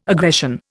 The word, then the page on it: aggression: Indian